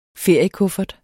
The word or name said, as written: feriekuffert